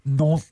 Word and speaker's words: Nantes: Let's get the pronunciation down as well. Give us that again. – Nantes.